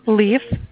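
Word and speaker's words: Leif: How do you say his first name? – Leaf. – Leaf? Oh, figured E before the I.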